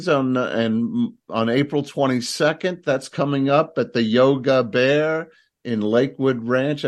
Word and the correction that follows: Yoga Bar: Am I saying that right? I kept wanting to say Yogi Bear or Yogi Bear. – The Yoga Bar.